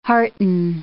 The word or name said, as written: hearten